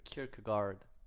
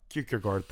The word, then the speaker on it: Kierkegaard: And I, I do want to read a quote from Kierkegaard. I don't know if I pronounce it very well. There... – Kierkegaard. – Kierkegaard, thank you so much.